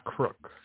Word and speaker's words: Crook: Crook. C-R-O-O-K.